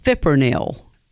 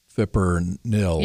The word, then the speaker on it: fipronil: How do you pronounce it again? – Fipronil. I think it starts with an F, but I can't be positive. – Well, fipronil. – Yeah, here it is. It's fipronil.